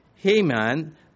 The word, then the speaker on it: Heman: You could say He-Man. It's probably more closely pronounced He-Mon, but He-Man is how we're going to do it.